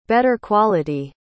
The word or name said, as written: better quality